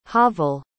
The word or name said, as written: havel